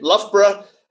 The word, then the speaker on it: Loughborough: How do you say Loughborough? – Yeah, pretty good. Loughborough.